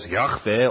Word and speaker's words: Yahweh: In the Hebrew language, it's four letters that come together just to pronounce it as Yahweh, or in English, Jehovah.